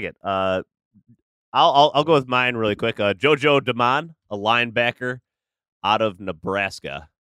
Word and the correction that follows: Domann: It's not. How did you say it? That's not how you say it. – Doman? – How's it spelled? – D-O-M-A-N-N. Doman? Johansson JoJo Doman. – Doman.